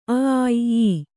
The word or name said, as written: ಈ